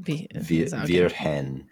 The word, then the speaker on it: Virgen: It's J-U-A-N-A-L-A. And then Vihen. – Virgen. The E after the G is like a soft G.